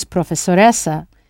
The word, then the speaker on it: professoressa: And if the professor is a female, use professoressa with two hissing sounds. It is spelled P-R-O-F-E-S-S-O-R-E-S-S-A, professoressa.